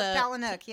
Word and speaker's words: Palahniuk: Chuck Palahniuk, yeah.